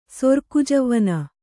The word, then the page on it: ಸೊರ್ಕುಜವ್ವನೆ: sorku javvane